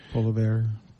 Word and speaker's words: Poilievre: Poilievre.